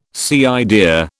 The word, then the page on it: cider: British English